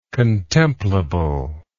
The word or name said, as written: contemplable